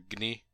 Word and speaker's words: Gny: But you can find Danish folk music by groups such as Virelæ or Gny, which is G-N-Y. And yes, I had to look up how to pronounce G-N-Y. It's Gny.